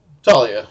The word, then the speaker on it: Talia: Talia, yeah.